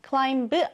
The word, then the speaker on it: climb: 여기서 발음 주의해 주셔야겠죠? climb 아니고 climb, 그리고 mountains가 됩니다.